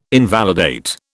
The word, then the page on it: invalidate: British English